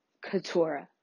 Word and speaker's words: Keturah: So, this is my first podcast, and my name is Keturah, spelled like K-E-T-U-R-A-H, pronunciated like Keturah.